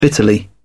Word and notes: bitterly: British English

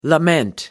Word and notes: lament: American English

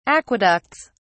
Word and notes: aqueducts: British English

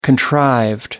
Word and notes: contrived: American English

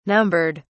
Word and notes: numbered: British English

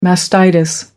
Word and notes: mastitis: American English

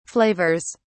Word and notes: flavors: British English

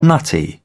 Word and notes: nutty: British English

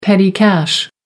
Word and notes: petty cash: American English

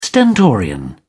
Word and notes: stentorian: British English

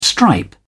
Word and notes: stripe: British English

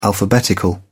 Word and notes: alphabetical: British English

